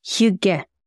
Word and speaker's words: hygge: And there is a word, I don't know if it's from the Danish or Norwegian vocabulary, but it's called hygge. Hygge. And it's spelled H-Y-G-G-E.